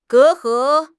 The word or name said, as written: 隔阂